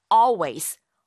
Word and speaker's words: always: 실제 발음할 적에는요. 어 하는 그 올, 리을 발음을 아예 하지 않아요. 그래서 always 라고 발음하게 됩니다.